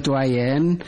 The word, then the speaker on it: عيان: نعم، كلمة عيان تنطق بكسر العين، من الفعل عاين يعاين معاينة، وعيانا نقول رآه بعينه، ولقيته معاينة وعيانا، أي أنني لم أشك في رؤيتي إياه.